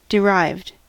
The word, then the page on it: derived: American English